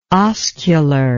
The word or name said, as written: oscular